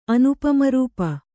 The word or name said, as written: ಅನುಪಮರೂಪ